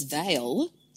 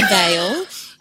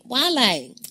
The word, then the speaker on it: Vale: And people might say, oh, I thought it was Vale. It's not. Oh. You pronounce the V's as W's, and you actually pronounce the whole thing. So you don't say whale or vale. You say Vale.